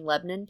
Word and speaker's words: Lebanon: Lebanon, PA. And Lebanon is pronounced Lebanon, but it's spelled Lebanon.